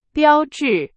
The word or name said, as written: 标志